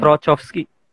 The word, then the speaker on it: Trochowski: uh, terus yang selanjutnya itu ada uh, eh, Trowski. Tro ya bacanya. Trochowski. Trochowski.